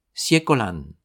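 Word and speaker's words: Siekolan (謝閣蘭): Le tout se prononce Siekolan,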